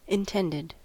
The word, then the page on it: intended: American English